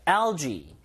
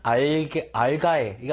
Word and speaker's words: algae: algae. algae. 이게 복수형 단어입니다. algae. 실제 복수형으로 많이 쓰니까 읽을 때 희한하죠? 알가에 이거 아니라 LG라고 읽어요.